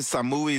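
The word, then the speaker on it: Samuy: Samui is how you say it.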